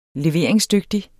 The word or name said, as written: leveringsdygtig